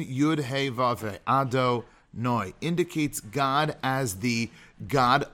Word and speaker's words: Adonai: Hashem, which is spelled with the Yud, a Hey, and a Vav, Hey, which we pronounce Adonai, we say is Elokeinu.